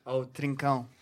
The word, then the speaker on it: Trincão: Trincão.